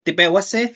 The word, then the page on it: tipeuasej: Náhuatl